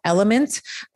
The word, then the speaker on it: LMNT: So if you are salting your food, I use a supplement called Element. It's spelled L-M-N-T.